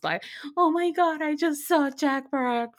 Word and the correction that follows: Barakat: from Old Time Low. Oh, Barakat. Barakat. I never know how to say his last name.